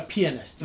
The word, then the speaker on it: pianist: A pianist, yes. That's the proper pronunciation. Just like how you say for someone who plays the flute, you don't say flute player, you say flautist.